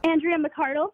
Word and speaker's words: McArdle: Andrea McArdle.